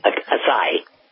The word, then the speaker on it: acai: Acacia berries. Am I saying that right? A-C-A-I. – Yep. – Okay. – acai. – Acai, okay.